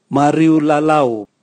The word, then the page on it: mariulalau: Raga